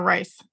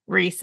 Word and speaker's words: Riess: Is it Jana Reese? From the Religion News Service? Or is it Jana Rice? – Jana Reese.